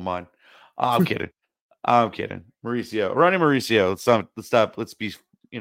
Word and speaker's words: Mauricio: Ronnie Marchuccio, I believe is how you say that. – Mauricio. – Ah, okay. Wow. – Okay. You're close. Close enough.